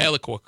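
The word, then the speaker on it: Alaqua: And again, how do you pronounce her first name? – Eloqua Cox, I believe.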